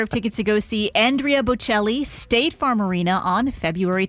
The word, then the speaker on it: Andrea Bocelli: Andrea Bocelli.